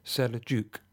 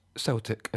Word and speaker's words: Seljuk: The name of the tribe of Turks at the Battle of Manzikert, spelt Seljuk, is pronounced, as is logical, Seljuk, not Celtic, as I said.